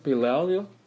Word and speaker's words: Belial: The torrents of ungodliness is actually literally the floods of, I can't pronounce this word, Belial, okay? B-E-L-I-A-L.